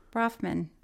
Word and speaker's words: Bronfman: the Bronson, am I saying it right? Sisters, how do you say their names? Bronfman.